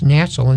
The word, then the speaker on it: natzel: And another word that's here in the Hebrew is the word pronounced natzel in the Hebrew, N-A-T-Z-E-L, which means a snatching or a quick removal from the earth.